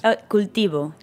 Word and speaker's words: Cultivo: Cultivo. – Cultivo. – Cultivo. – And do you say Queseria? Cultivo? – You say Queseria Cultivo.